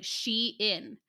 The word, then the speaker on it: Shein: And when I Googled it from their Twitter account, they said it was like Shein. Apparently lots of people have been saying it wrong.